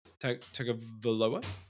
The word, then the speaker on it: Tagovailoa: Tagovailoa? – Sure.